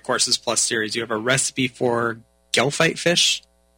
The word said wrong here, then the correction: gefilte: Is that, I don't know if I'm pronouncing that correctly. – Gefilte.